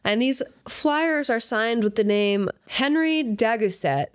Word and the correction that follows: Henri: Is it Henri? – Henri. Yeah, I wanted to say it like that, but I didn't. Henri. Now I can.